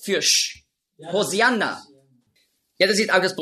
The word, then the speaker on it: Hosianna: Hosianna ist einfach die griechische Aussprache von Hoshi'anna. Hosianna, das ist die griechische Aussprache. Weil die Griechen, die haben im Alphabet kein Sch-Laut. Nurz. Die Hebräer schon. Auf Hebräisch sagt man Hoshi'anna. Aber Griechen können das nicht.